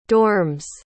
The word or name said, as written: dorms